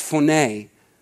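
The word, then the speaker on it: phoné (φωνή): The Greek word for voice here is pronounced phoné. It's pronounced phoné,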